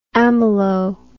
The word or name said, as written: amylo